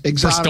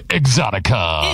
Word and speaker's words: Exotica: It's like, it's exotic. – Don't say it that way. You say exotica. – Exotica. – It's exotic. What is it? Well, it's spelled E-X-X-X.